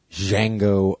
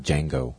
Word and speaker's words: Django: Number 4, Django Unchained. – Oh, yeah. All right. That's that Quentin Tarantino. – The D is silent.